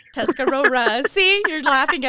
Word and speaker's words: Tuscarora: It's Tuscarora, and that is the English word for it. It's really Scarorette, but, you know, it's Tuscarora.